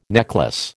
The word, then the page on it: necklace: American English